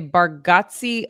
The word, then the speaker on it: Bargatze: And I had to try to say Bargatze at least 10 times to make sure that I nailed it.